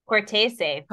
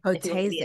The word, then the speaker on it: Cortese: Cortese. – Cortese.